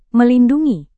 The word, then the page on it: melindungi: Indonesian